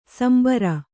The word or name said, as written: ಸಾಂಬಾರ್